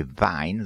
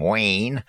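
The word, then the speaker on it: Wein: If I do the correct pronunciation, it would be Vine, though I suppose Americans would call him Wayne.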